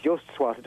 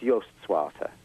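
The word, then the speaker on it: Joost Swarte: Is that how you pronounce it? Suarto? – It's Joost Suarto. It just means Joe Black. It sounds so exotic. Joost Suarto or something, but it's Joost... I think it's Joost Suarto.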